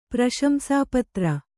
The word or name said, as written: ಪ್ರಶಂಸಾಪತ್ರ